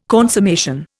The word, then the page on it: consummation: Indian